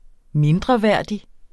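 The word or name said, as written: mindreværdig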